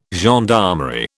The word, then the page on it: gendarmerie: British English